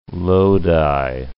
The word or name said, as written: Lodi